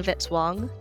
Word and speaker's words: Vitzwong: Music by Kevin Vitzwong, rhymes with hit song.